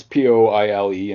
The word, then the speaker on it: Poile: how would you pronounce Poile in French? – Poil. – Poile.